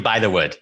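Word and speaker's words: Bythewood: It's definitely By the Wood.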